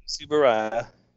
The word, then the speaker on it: Tsuburaya: Tsuburaya.